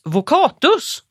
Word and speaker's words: vocatus: Det är en utveckling av latinets vocatus. Eller hur, Dick? – Så uttalas det.